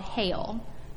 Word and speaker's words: Geel: To show this blessedness, the way that communities can be blessed, I want us to visit this town, this Belgium town called Heel, which is spelled G-E-E-L.